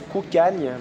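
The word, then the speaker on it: Cocanha: Ça se prononce Kukagne, mais ça s'écrit Kokania, Kokana.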